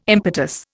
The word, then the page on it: impetus: Indian